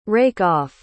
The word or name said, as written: rake-off